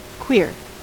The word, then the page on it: queer: American English